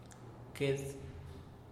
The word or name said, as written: qued